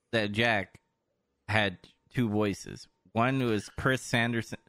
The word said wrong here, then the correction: Sarandon: Sarandon. I don't know how to say his name. He was the Sarandon. – Yeah, Sarandon.